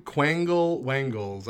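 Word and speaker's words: Quangle Wangle: Lear, an English poet who is known for his nonsense style, often writing with made-up words, telling tales of quaggle waggles and... – That is not how you pronounce that made-up word. – Quaggle wag... – Quangle wangles, I'm almost certain, is how it's pronounced.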